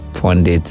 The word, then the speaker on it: Pundit: My name is Henry Pondit on all social media platform. It's Henry Pondit. Pondit is spelled P-U-N-D-I-T.